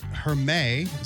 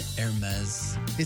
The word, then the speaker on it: Hermès: The high-end fashion house Hermès, is how you say it, isn't it? The company is worth $220 billion. – Isn't it Hermès? – It's with an S. I didn't know if you do the S or not.